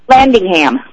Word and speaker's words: Landingham: Landingham.